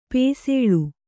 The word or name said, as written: ಪಸುಳೆ